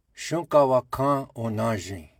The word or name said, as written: Shunkawakan Onazin